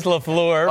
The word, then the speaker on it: LaFleur: Matt LaFleur. It's LaFleur. Oh, but no, I know.